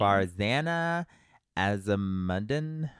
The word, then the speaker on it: Farzana Azamuddin: Farzana Azamuddin. Oh, my goodness. – I actually think you said that right. – Oh, great. – Yeah, sounds right. – It's almost like Tarzan.